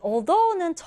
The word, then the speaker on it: although: 이 단어는 디딤절의 강세를 두고요. 그리고 이때 TH 발음 주의해야 돼요. 이때 이 발음 기호는 혀를 윗니 아랫니 사이에 대고 성대를 울리는 발음입니다. 그래서 although인데요. 이 although는 접속사로 쓰여서요.